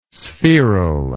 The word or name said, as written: spheral